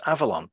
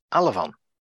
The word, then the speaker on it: Avalon: At approximately 4 minutes 30 in the episode, Michael York mispronounces Avalon by instead saying Alavon.